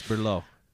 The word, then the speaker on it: Blériot: Oh, the guy's name was Blériot. I'm probably butchering that. – Right? It's Berlot.